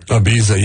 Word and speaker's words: Ibiza: But anyways, she's going to Ibiza, if I'm saying that right. Ibiza, whatever. – Ibiza, yeah, that's the swanky place where the rich and famous go to party it up like nothing else.